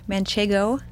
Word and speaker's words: Manchego: Manchego. – Yes. – Is that how you say it? – Yeah, Manchego.